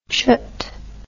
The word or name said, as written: chut